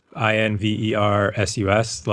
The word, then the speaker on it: Inversus: It's spelled Inversus, like inverses as in the competitive versus.